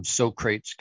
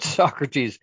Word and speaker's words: Socrates: Socrates, as soon as I see that, I think of Bill and Ted's Excellent Adventure, and they called him Socrates because that's how it's spelled. But Socrates, again, that's an education thing, right?